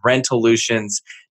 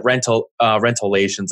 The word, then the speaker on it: Rentolutions: I actually said their name wrong on one of my preview videos, like way back when. But it's okay. I called it Rentolations, I think is how I pronounced it.